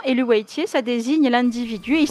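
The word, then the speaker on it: Eluveitie: Alors moi, je le prononce Elouaitier.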